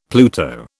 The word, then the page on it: Pluto: British English